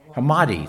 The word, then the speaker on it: Hammadi: And this Gospel of Thomas came from the Nag Hammarskjöld, or Hamish, how do you say it? Hammadi, thank you, there I got it.